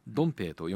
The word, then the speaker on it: 東北: 26 歳だったそうですが、 5人姉妹の末っ子で、中国東北地方、ドンペイと読むんだそうですが、